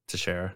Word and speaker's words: Teixeira: Jack Douglas Tejera, is that how you say it? Tejera? – Teixeira.